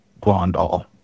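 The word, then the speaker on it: Grondahl: So Daniel James Blondahl is my...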